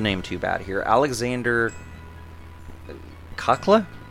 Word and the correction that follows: Kacala: Kacala. – Kacala, and uh, Kacala.